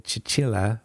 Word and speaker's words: Cecilia: First, I have a fantastic conversation with the amazingly talented Chichilla Ferry. She's the artist behind Brindlewood Bay, and she did our podcast art. Yeah, in English, her name is mispronounced as Cecilia. In her native Italian, it is Cecilia.